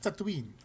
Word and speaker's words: Tatooine: Oh, did I not pronounce that right? It's not Tatooine? – It's pronounced Tatooine.